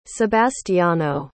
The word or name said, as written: sebastiano